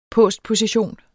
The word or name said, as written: postposition